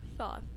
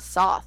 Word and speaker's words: Thoth: There's no S there. It's not Soth. It's Thoth. T-H-O-T-H.